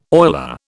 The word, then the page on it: oiler: British English